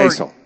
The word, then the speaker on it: Geisel: Geisel? – Seuss. – No, it's Theodore. – Geisel.